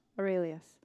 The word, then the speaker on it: Aurelius: Aurelius.